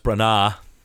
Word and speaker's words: Branagh: It's pronounced Branagh.